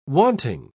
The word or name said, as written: wanting